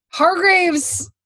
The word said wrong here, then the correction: Hargreaves: And by the way, I just realized I looked it up. It's Hargreaves. I am so sorry. It's Hargreaves. That's my fault. I said it wrong.